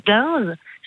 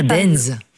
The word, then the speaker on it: Deinze: Et il y en a un, je m'excuse pour la prononciation, c'est Dinze. Ça s'écrit D-E-I-N-Z-E. – Ah, Dinze.